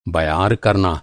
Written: बयार

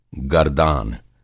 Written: गरदान

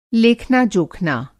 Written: लेखना-जोखना

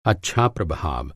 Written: प्रभाव

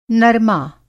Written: नरमा

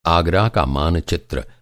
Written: आग

